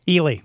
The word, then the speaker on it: Ely: A woman was killed and a suspect is also dead at a Santa Barbara home belonging to Tarzan actor Ron Ely. – Ely. – Ely.